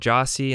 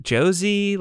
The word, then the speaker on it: Jossi: And I start telling people like, yeah, I started this company called Jossi. And they're like, what? Josie? Like to this day, people call it Josie and it's not, it's Jossi. It's J-O-S-S-I. That's how it's pronounced.